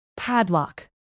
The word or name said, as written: padlock